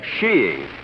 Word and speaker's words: skiing: shiing. Sheeing. Oh, there are other pronunciations, I suppose, but in England and Norway, sheeing is considered most acceptable.